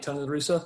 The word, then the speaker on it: Tony La Russa: Tony La Russa? Mm-hmm. I may have shared this before. – So. You said his name right, by the way.